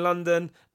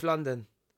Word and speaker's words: London: Now, I want to say, I think it's Steph Lon-Don. Like, I'm a Don.